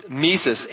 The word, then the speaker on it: Mises: It's Mises. M-I-S-E-S. .org. I think it's just Mises. .org. It might be von Mises. .org. V-O-N-M-I-S-E-S.